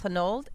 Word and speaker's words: Honnold: I don't know how to pronounce his last name, though. Alex Honnold. H-O-N-N-O-L-D. – Honnold, yeah. – Honnold. Mm-hmm.